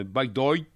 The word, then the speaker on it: Bygdøy: Bygdøy. Je le prononce à la norvégienne. Hein. – Bygdøy.